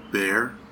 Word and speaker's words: Baehr: Baehr. – Okay. – Actually, my name, the way I spell it, B-A-E-H-R, is the name of the street that I grew up on.